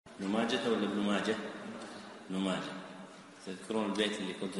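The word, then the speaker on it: ماجه: يا صاحي نطق ماجة بالها بلا لجاجة، يا صاحي نطق ماجة بالها بلا لجاجة، يعني كلمة ماجة تنطق بالها ولا يقال ماجة.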